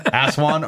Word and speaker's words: Aswan: This is coming from Aswan, Egypt. Is that how you pronounce it? A-S-W-A-N, Aswan or Aswan? – Make it a little more Arabic in there. Aswan. – Aswan, Egypt. – That was very Spanish.